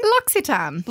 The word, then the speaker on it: L'Occitane: L'Occitane.